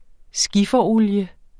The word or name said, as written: skifferolie